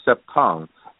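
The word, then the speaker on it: Cepton: It's actually pronounced as Septon. The first part, C-E-P-T, is actually part of the word perception, C-E-P-T. And then O-N is like photon.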